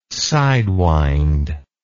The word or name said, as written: sidewind